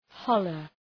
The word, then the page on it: holler: ‘hɒlər